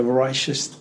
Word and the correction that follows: Rorschach: Yeah, so you did a Rorschach test.